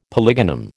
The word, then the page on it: polygonum: American English